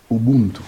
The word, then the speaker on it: Ubuntu: Ubuntu, je le dis bien? Ubuntu. – Oui, Ubuntu.